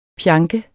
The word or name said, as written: PJANKEDES